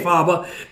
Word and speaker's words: Fabre: Well, basically the Fabre line is pronounced both ways. So you weren't incorrect in saying Fabre because that's how it's spelled. Fabre is the name that was used generally here in Rhode Island. Just like in Maine, it's Calais, Maine, rather than Calais.